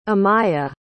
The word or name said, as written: amaia